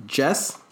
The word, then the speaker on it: Djesse: It's pronounced Jess, D-J-E-S-S-E, but I think you pronounce it Jess.